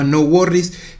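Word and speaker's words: NxWorries: Él es parte también de una banda muy interesante que se llama No Worries, que en realidad se escribe NX Worries.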